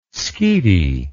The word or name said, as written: skete